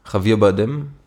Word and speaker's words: Javier Bardem: Und wenn ich, ich weiß wieder nicht genau, Javier Bardem.